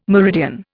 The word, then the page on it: meridian: Indian